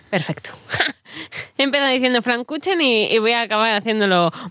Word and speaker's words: Flammkuchen: Voy a pronunciar el plato que nos has traído hoy, que según lo leo es Flammkuchen, que no se dice así. ¿Cómo se dice? – Se pronuncia Flammkuchen.